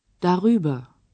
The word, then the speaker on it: darüber: Oigamos ahora un ejemplo con la preposición über como esta comienza con una vocal, para que no choque con la A de da, se intercala una R para pronunciar mejor la palabra. – Darüber.